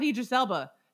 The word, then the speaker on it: Idris Elba: It was kind of like that with Idris Elba.